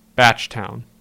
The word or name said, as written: Batchtown